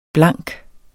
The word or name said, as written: blank